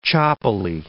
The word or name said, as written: choppily